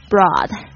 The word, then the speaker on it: broad: Sorely Sorely Next one 把它拼一下 S-O-R-E-L-Y Sorely Next one's broad B-R-O-A-D 别注意一下这个 O-A 的发音是 R 的声音，它是指宽广的广泛的 Wholesome Wholesome Wholesome H-W-H-O-L-E-S-O-M-E